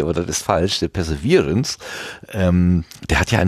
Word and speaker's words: Perseverance: Ja, äh, anders als alle anderen Rover hat ja dieser Perseverance, jetzt kann ich es glaube ich auch richtig aussprechen, also dieser Virance wird, muss betont werden. Ich, ich habe ja letztens Perseverance gesagt.